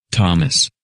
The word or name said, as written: Thomas